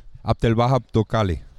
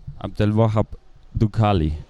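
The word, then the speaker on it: Abdelwahab Doukkali: Ich hoffe, ich habe das jetzt irgendwie richtig ausgesprochen. Wie heißt das? Sag du mal richtig, ich habe schon versucht. – Abdel Wahab Dukali. – Abdel Wahab Dukali. So hört sich das an.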